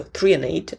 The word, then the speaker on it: threonate: 145 milligrams of magnesium threonate, so threonate, that's how you pronounce it, 145, and then we have 200 milligrams of magnesium bisglycinate.